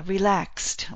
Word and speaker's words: relaxed: But we talked about the pronunciation of past tense, and X sounds like KS, and after a K, past tense will be a T sound, so relaxed. One syllable, laxed, one syllable, relaxed, not relaxed.